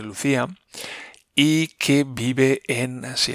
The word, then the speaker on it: LuzIA: y se llama Luz IA, y lo pronuncian Lucía.